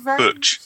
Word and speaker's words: Buch: Her name is B-U-C-H. – So how will you pronounce that? – Butch.